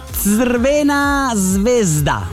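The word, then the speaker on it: Crvena zvezda: Allora, si dice Svena Svezda. Sarebbe la pronuncia.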